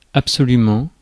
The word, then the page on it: absolutely: /æb.səˈluːt.li/; /ˌæb.səˈlut.li/; /ˈæb.səˌlut.li/